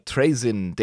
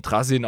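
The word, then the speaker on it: Trazyn: Ja, und verzeiht mir die englische Aussprache, ich bleib gerne bei den äh, Englischen, weil es einfacher ist. Äh, viele sprechen den Trasin aus oder sonst wie.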